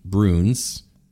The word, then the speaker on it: Bruns: George Bruns. – Yeah, not Burns. Good. – Not Burns. – You said it right.